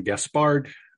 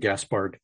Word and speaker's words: Gaspard: You pronounced it one of the two ways that is acceptable. My wife is more persistent about wanting Gaspard, but I've always had Gaspard. So I answer to either one.